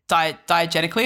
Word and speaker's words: diegetically: Like it all happens diegetically, am I saying that correctly? – Yeah.